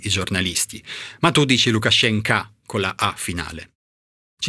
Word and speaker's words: Lukashenko: Perché quando la O, soprattutto in generale, non è accentata, si pronuncia A. E generalmente, quindi quando abbiamo la O alla fine dei cognomi, come Lukashenko, in realtà si dovrebbero pronunciare Lukashenko nella loro declinazione russa.